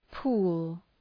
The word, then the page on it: pool: pu:l